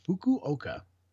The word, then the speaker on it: Fukuoka: Let's go from Shanghai to Fukuoka. Yeah, that's pronounced right. Fukuoka.